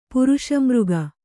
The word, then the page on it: ಪುರುಷಾಮೃಗ: puruṣāmřga